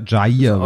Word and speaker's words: Jair: Miss ist Jair überhaupt richtig? – Äh, ja, Jair was glaube ich, ausgesprochen. Jair. Jair. Ja.